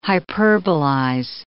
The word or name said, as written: hyperbolize